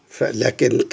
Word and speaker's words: لكن: لكن كلمة لكن تكتب لام كاف نون مباشرة، والألف فيها تنطق ولا تكتب.